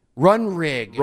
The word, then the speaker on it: Runrig: How do you spell that? – R-U-N-R-I-G. – Run Rig.